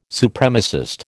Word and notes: Supremacist: American English

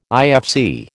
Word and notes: IFC: American English

